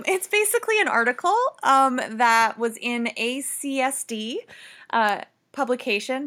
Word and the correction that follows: ASCD: Did I say that right? I always get them mixed up. – I think you did. – Or is it ASCD? – No, it's ASCD. Yeah. – I always say it opposite.